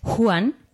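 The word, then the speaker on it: Huan: Como nos ha dicho Eleder en todas sus clases, muchas veces la H en élfico es aspirada. Pero claro, para nosotros es muy difícil de pronunciar y se confunde con la J. Entonces es Juan, pero claro, es muy fácil que salga Juan el perro, que parece un personaje de un cuento infantil.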